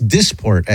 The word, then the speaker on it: Dysport: I think it's Dysport, actually, is how I think you pronounce it. I believe, but I'm not positive.